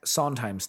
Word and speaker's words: Sondheim: You know, so obviously we had like Steven Soderheim and is it Soderheim? I can't pronounce it. Is it Steven Soderheim? – Sondheim.